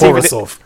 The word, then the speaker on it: Borisov: It's Borisov. – I've even pronounced it wrong in the intro as well, so don't worry about that, which I pre-record. Basaraf. Yeah, Borisov. I've actually written it down as Borisov as well, so you know.